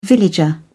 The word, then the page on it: villager: British English